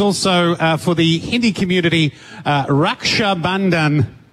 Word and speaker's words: Raksha Bandhan: We both have great sisters, and we celebrate them today with Raksha Bandhan, which is amazing.